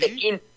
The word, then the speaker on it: Pekín: Ajá, así, sí, Pekín, sí. P-E-K-I-N.